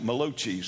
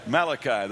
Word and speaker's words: Malachi: That last one, Malachi, somebody said one time they pronounced it Malachi, said that's an Italian prophet. But Malachi, that's the last of the minor prophets.